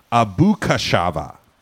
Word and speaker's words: ABACAXUVA: It's spelled ABACAXUVA. It's pronounced Abukashava.